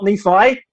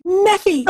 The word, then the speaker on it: Nephi: Oh, no, not Nephi. Say it. How do we pronounce the name? – Nephi.